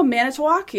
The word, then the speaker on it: Manitowoc: Oh, Manitowoc.